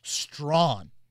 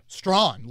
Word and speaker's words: Strachan: I think it's actually, and again, I asked for these pronunciations because this one will confuse the hell out of me. Strachan is what I was saying. Strachan, okay. – Strachan, like I think we're taking out the CH. – Oh, just Strachan. – Yeah, that's what I was told.